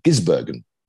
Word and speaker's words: Gisbergen: I always pronounce it wrongly. Gisbergen.